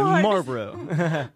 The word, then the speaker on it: Marlboro: Marlboro.